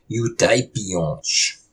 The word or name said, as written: Yotay piyôsh!